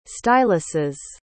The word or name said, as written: styluses